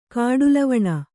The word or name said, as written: ಕಾಡುಲವಣ